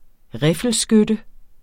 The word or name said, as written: riffelskytte